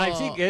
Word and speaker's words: Leipzig: Es Leipzig, es lo que nos dijeron que teníamos que decir.